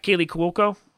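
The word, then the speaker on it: Kaley Cuoco: Yeah, Kaylee Cuoco.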